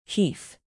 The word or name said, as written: heath